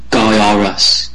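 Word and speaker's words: Gyruss: and you pronounce the game Gyarus,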